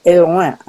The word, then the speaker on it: Hellouin: H-E-L-L-O-U-I-N, and it's pronounced Helouin, I believe.